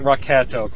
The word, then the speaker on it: Rocketo: Well, I pronounce it Rocketo, quite frankly. I went to Frank Espinosa's website, and there was a big discussion on that, because I was curious, because I pronounced it that way on my podcast, and several people called me on it. And if I read his phonetic spelling, it is Rocketo.